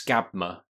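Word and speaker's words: Skábma: But it's just to correct us, it's and I'm not going to pronounce it correctly, but it's Skabma, S-K-A-B-M-A. Oh, Skabma, yeah, that's right.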